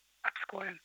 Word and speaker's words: Обское: И вы все верно сказали, у нас есть Обское море, Обское, Обское, все говорят по-разному, но правильно говорить Обское.